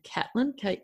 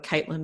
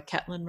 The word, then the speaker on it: Caitlin: I think she actually pronounces her name Catelyn. In my brain, I always call her Caitlin Moran, but I think she actually goes by Catelyn Moran, who is a newspaper columnist, but she has written novels as well, which I highly recommend dipping into.